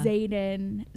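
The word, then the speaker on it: Xaden: Zayden saw the interaction, and right. he was able, did I say his name right? – Yeah, he did.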